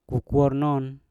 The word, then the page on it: kokuor non: Mpur